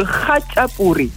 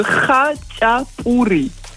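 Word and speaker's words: khachapuri: Exactement, mais je prononcerais plutôt le kacha pourri.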